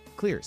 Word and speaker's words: Xlear: That's Xlear spelled with an X for his take on lessons he's learned through research and development on his line of xylitol products.